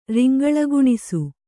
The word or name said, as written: ರಿಂಗಳಗುಣಿಸು